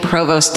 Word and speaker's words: Provost: But it turns out that no historical landmark in Charleston is as well recognized as the Old Exchange and Provost Dungeon, Karen.